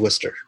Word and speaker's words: Worcester: The city is Worcester. – Mm-hmm. – The Massachusetts.